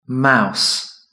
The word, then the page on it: mouse: British English